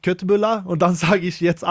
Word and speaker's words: köttbullar: Ich weiß nicht, ich probiere das Deutsch zu sagen oder etwas, aber das heißt eigentlich Schöttbullar. – Eigentlich Schöttbullar, okay.